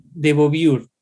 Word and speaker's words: Beauvoir: con algo que decía Simón de Beauvoir, no sé si lo pronuncié bien. De Beauvoir.